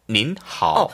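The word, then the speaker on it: 닌 (您): N 발음이 밑에 붙어요. 네네. 닌이라고 했으면 닌.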